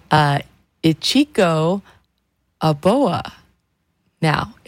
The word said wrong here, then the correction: Aoba: if I said it incorrectly, oh, I think I said it right, Aboa. Aoba, there we go.